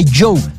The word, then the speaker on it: Djo: Coming up next, we have a new track by Joe. Remember, his name is spelled D-J-O, but pronounced Joe.